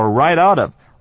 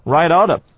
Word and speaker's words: right out of: 再来是 right out of。三个字合起来念的时候全部都连在一起，我们念作 right out of, right out of 这样的念法。